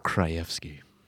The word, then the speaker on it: Krajewski: How do you say your last name? – Krajewski. Doesn't it just roll off the tongue? – Krajewski. – Krajewski. – There you go. Now you all know.